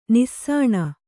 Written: ನೆಸಣ

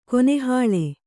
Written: ಕೆನೆಹಾಲು

